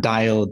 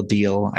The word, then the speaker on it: Diehl: Last name is spelled D-I-E-H-L. – So I've been saying it wrong. – Yeah, dial, deal.